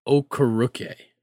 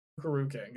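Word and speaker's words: Okereke: All right, I just looked at the pronunciation. It's Okaruke or Okuruke. – Okaruke. – Okaruke, got it.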